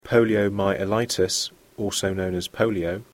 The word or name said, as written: poliomyelitis